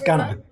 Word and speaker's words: Guner: So it's Gunner.